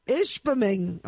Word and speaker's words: Ishpeming: Yeah, I was going to say, I was really proud of you for pronouncing Ishpeming properly.